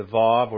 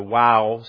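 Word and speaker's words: Waw: And for you 1.5 Hebrew students, that's the Vav or the Wow stanza, depending on if you want to pronounce it like Jesus or not. If you want to pronounce it like Jesus, it's the Wow. If you want to pronounce it not like Jesus, it's the Vav.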